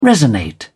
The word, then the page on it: resonate: British English